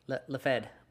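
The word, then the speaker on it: Laughead: Charles, I'm going to say his last name is, it's spelled Laughhead. – Like actually Laughhead? – It's L-A-U-G-H-E-A-D. – Lafed. – Okay, Lafed, whatever. Charles Lafed in 1953, he's the one who founds it with this other woman named Dorothy Martin.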